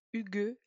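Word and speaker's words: hygge: H-Y-G-G-E. J'avais cherché, a priori ça se prononce comme le prénom Hugues en accentuant le E.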